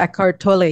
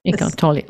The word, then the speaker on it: Eckhart Tolle: Oh, yeah, Eckhart Tolle, yes. – Eckhart Tolle, okay.